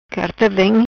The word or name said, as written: Carta vem